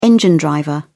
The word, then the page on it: engine driver: British English